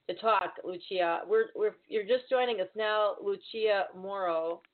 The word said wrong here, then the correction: Amaro: Amaro, I'm sorry. Am I saying it right? Amaro, yeah.